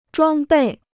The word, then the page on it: 装备: zhuāng bèi